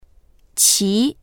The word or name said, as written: qi2